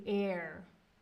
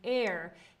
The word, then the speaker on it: heir: So, oh, he was his grandmother's only heir. You don't pronounce that H. So the way it's spelled looks very different than the way you pronounce it. So it's heir. He was his grandmother's only heir.